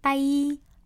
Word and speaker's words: tai: 위에 거요, 스펠링 T-A-I 이렇게 따이라고 있는 거는요, 베트남어로 귀라는 뜻이에요. – 귀요? – 귀요, 귀. – 아. – 네, 듣는 귀. 네. 그리고요, 아래요, T-A-Y 있잖아요.